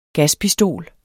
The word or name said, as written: gaspistol